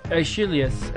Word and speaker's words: Aeschylus: How do you say this name? Aeschylus. – Aeschylus.